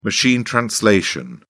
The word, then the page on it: machine translation: British English